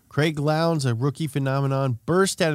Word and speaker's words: Lowndes: There was also a new generation of drivers, including Mark Skife and Craig Lowndes. – Rhymes with clowns.